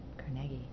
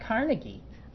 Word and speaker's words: Carnegie: Carnegie. I said it right? Carnegie. Because I learn. – You New Yorkers don't say it right. It's not Carnegie.